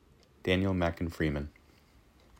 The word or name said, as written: Mackin Freeman Daniel